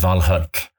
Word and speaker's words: Valhöll: And I say creation because that too, this idea that Snorri underlines of the Valhöll, Valhalla, and the proper pronunciation is Valhöll, the Hall of the Chosen.